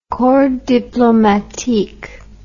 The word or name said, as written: corps diplomatique